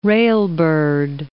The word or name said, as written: railbird